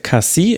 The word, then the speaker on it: Cassie: Wiedmer, Bell und Cassie, er wird anscheinend doch Cassie ausgesprochen und nicht Kass, so wie ich es in der Saisonvorschau gemacht habe. Sorry, ich hatte damals noch gelesen, dass es genau andersrum sei.